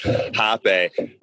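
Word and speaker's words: rapé: And, you know, they were before we sat for our first ayahuasca ceremony, they're like, do you want to do some hape? It's spelled rape, but it's pronounced hape.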